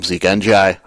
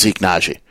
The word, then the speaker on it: Nagy: I've been calling him Zeke NGI. I don't know why. It is Zeke Nagy.